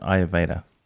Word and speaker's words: Ayurveda: And today I'd like to focus on what you know around Ayurveda.